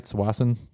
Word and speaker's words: Tsawwassen: There's a place south of Vancouver called Tsawwassen, and it's about twenty something miles south of downtown Vancouver. Am I saying that right? Tsawwassen. – Yep.